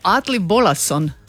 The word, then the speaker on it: Atli Bolason: I on je zapravo Atli Bolason, ako sam dobro izgovorila. Da. – Pa ja se mislim da se dobro izgovorila, radi se ipak osobi sa Islanda, on je dio njihovog kolektiva, tog šireg kolektiva Guz Guz, ali isto tako sudjeluje u nekom...